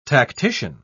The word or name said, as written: tactician